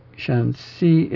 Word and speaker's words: Shanxi: Shanxi with an ascending syllable as the second syllable. No, it's not. Wait a minute. It's not ascending. It's not. Shanxi, it's the higher pitched, even pitch, the second syllable. But the shan is not even pitched.